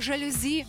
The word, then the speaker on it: жалюзі: Який наголос пасує найкраще для цього слова? Давай з'ясуємо разом. Насправді тут усе дуже просто. Якщо знати, звідки ля це слово приїхало до нас. А воно має французьке коріння. В французькій мові, між іншим, наголос він завжди або здебільшого обирає останній склад. Тож правильний варіант тут лише один – жалюзі із наголосом на «і».